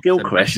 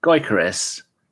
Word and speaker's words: Gyökeres: It's not Gyökeres, is it? Gyökeres. – No, it's Gyökeres. – I thought you pronounced the O.